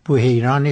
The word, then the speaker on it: بحیرا: اولا صحیحش بحیران است، بحیراست. خواهش میکنم بحیرا تلفظ بفرمایید. – بله، پس بحیرا تلفظ غلط مشهوره. – بله، غلط خوب. مشهوره، بحیرا درسته.